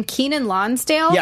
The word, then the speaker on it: Kenan Lonsdale: So I believe this is how you say his name, Kenan Lonsdale. – Yes.